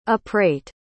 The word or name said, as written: uprate